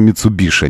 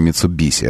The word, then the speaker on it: Mitsubishi: Skoda Kodiaq и Mitsubishi, говорится Mitsubishi, а не Mitsubishi. Это уже каноническое произношение, которое было опубликовано руководством компании, японским руководством компании Mitsubishi в России.